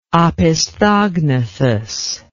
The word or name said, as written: opisthognathous